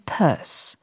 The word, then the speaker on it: Pierce: Man hört den ironischen Ton des Gedichts, denn Frederick Pierce, der 14. Präsident der Vereinigten Staaten und der einzige, der aus New Hampshire kam, muss natürlich nur deshalb Perse ausgesprochen werden, damit er sich wohl oder übel auf Verse reimt.